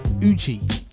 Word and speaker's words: Ujjy: Record Jet Records, and then um, Uji. I think it's pronounced like that U J J Y.